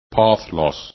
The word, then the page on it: path loss: english